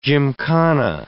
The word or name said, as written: gymkhana